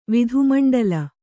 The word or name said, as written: ವಿಧುಮಂಡಲ